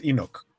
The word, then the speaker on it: Enoch: Pues la pronunciación en inglés, no según sé. Yo, es Enoch.